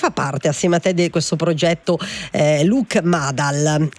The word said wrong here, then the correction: Mabal: Spero di pronunciarlo bene a livello di pronuncia, però insomma tutti i super musicisti, ok, da questo è capisco che mi vuoi correggere la pronuncia. – Sì, Mabal.